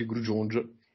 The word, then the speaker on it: Grudziądz: È Grudziądz, però si legge Grudziądz.